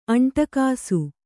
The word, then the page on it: ಅಂಟಗಾಸು: aṇṭagāsu